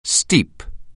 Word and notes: steep: anglais

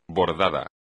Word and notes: bordado: espagnol

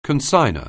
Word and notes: consignor: anglais

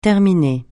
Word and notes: terminer: francais

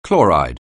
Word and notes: chloride: anglais

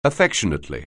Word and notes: affectionately: anglais

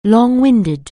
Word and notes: long-winded: anglais

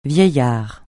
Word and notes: vieillard: francais

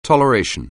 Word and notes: toleration: anglais

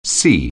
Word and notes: C: anglais

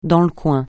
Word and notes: dans le coin: francais

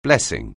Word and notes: blessing: anglais

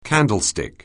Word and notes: candlestick: anglais

